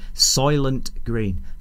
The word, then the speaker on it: Soylent Green: Soylent Green.